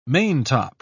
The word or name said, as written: maintop